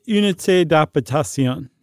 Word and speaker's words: Unité d'Habitation: The Unité d'Habitation.